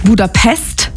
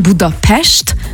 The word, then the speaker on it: Budapest: Also erstens appreciaten wir das schon mal, wenn man nicht Budapest sagt, sondern Budapest, weil das S immer ausgesprochen wird wie ein Sch.